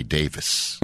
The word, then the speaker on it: Davies: Like the Kinks, Ray Davies. It's not. It's Ray Davis.